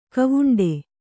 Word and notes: ಗಾವುಂಡು: gāvuṇḍu

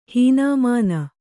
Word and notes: ಹೀನಾಮಾನ: hīnāmāna

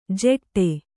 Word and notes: ಜೆಟ್ಟೆ: jeṭṭe

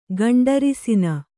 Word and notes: ಗಂಡರಿಸಿನ: gaṇḍarisina; kannada

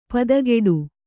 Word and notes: ಪದಗುಡು: padaguḍu

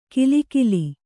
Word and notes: ಕಿಲಿಕಿಲಿ: kilikili

